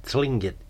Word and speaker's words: Tlingit: And one more bio to add, Dave Stevenson, as I try to pronounce it, T-L-I-N-G-I-T, Tlingit Eagle, Tlingit and Haida, H-A-I-D-A tribes from southeast Alaska.